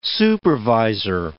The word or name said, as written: supervisor